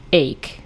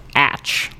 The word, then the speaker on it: ache: Originally, the verb was pronounced ache, the way we use it now, but the noun was pronounced atch, like to rhyme with batch, because it followed the same conjugation, I guess, of like speak and speech.